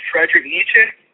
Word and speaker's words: Friedrich Nietzsche: So what is your response to, and I can't even pronounce this German philosopher's name. Can you pronounce it for me? – Friedrich Nietzsche. – Okay, Friedrich Nietzsche.